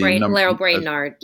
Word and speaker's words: Lael Brainard: And then appointing Lael Baird, I think that's how you pronounce her name, as the Lael Brainard, yes. – Brainard, right, as the number two.